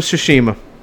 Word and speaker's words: Tsushima: Yep, Ghost of Tsushima.